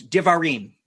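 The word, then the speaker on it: Eilu D'varim: Elu Davirim. How close am I? – Close. Divarim. – Divarim.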